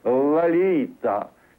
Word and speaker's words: Lolita: Lolita. Now comes the rush. – Note, by the way, that the first syllable of her name sounds more like an R sound than the O sound. Lalita, свет моей жизни, огонь моих чресел, грех мой, душа моя, Лалита.